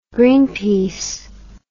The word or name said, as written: Greenpeace